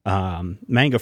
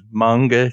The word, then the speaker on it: manga: This is going to be an American version of the popular manga franchise, which is, I think, has seven movies internationally. – Believe it's pronounced manga.